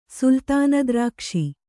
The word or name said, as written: ಸುಲ್ತಾನದ್ರಾಕ್ಷಿ